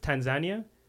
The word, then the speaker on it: Tanzania: And we were thinking of doing, actually, until recently, what's been happening there is it was one on our list, Tanzania. – Why do you say it like that? – That's how it's supposed to be said. – Oh, okay. – Is there another way of saying it? – Tanzania.